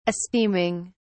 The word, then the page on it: esteeming: British English